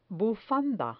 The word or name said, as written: bufanda